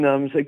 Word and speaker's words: numb: Euh, comfortably numb. Comfortably, ça s'écrit dans un mot. Puis numb, c'est N-U-M-B.